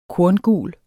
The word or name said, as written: korngul